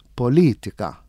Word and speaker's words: politika: Jekk jiena nimxi ma dan li jiena issa, jekk sa aħħa fuq l-aħħar vokali, sa tiġi politika. Mhijiex. Jekk sa aħħa fuq ta' qabel tal-aħħar sa tiġi politika li mhijiex lanqas. Allura qiegħda t-tielet sillaba mill-aħħar politika. Politika.